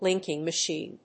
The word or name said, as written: linking machine